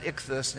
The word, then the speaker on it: ichthus: We pronounce it ichthus, and if you know anything about fish, ichthology, yes, that's where it comes from.